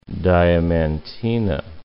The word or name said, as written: Diamantina